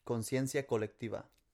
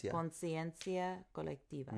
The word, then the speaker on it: Conciencia colectiva: Conciencia colectiva. – Conciencia. Conciencia colectiva.